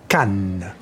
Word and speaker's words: Cannes: Oh, we should launch in conjunction with the Festival du Con. – Actually, it's pronounced Cannes.